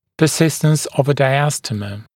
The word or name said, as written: persistence of a diastema